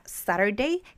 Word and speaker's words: Saturday: También acuérdate que cuando dos vocales están entre una consonante, por ejemplo, si una, si una consonante está entre dos vocales, como en la palabra Saturday, que se escribe Saturday, esta R esta T, perdón, va a funcionar como una R. ¿Por qué? Porque la T está entre dos vocales.